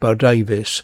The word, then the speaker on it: Bodewes: And Bo Davis is actually spelt Bodewes, but it's pronounced Bo Davis, and a very interesting artist who's done very well for herself over in the Netherlands.